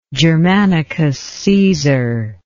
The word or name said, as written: germanicus caesar